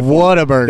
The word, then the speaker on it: Whataburger: Whataburger.